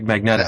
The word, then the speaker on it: Magneto: took him 40 years to learn how to say Magneto, but he got yes. after only. – I, I do say it right, Magneto.